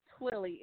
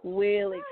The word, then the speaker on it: Twillie: Oh, and how you say my name is Twillie. It's like Willie with a T in front of it. So Twillie Cottingham.